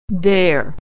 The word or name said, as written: dare